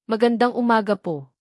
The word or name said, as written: magandang umaga